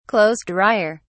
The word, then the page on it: clothes dryer: British English